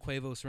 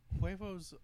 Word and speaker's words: huevos: I've heard of Quavos Rancheros. – I don't know what that is. I've heard of it. – I think it's pronounced Huevos.